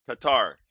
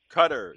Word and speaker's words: Qatar: And Another thing is the country I hear mispronounced so much, Q-A-T-A-R. – Qatar. – It's not Qatar. It's pronounced to rhyme with the dairy product, butter. – Cutter. Yeah, you're right.